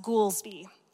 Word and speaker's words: Goolsby: Before I married Jason, my last name was Goolsby. G-O-O-L-S-B-Y. And it's very hard as a child to have a last name that rhymes with Foolsby.